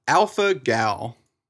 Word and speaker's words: alpha-gal: alpha-gal, I think.